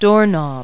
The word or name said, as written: doorknob